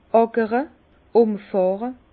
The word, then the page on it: àkre: Bas Rhin; Herrlisheim